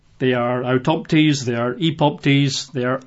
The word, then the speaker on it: epoptes: Or the other Greek word would be epoptes, E-P-O-P, T-E-S, E-P-O-P, T-E-S, and he references 2 Peter 1:16.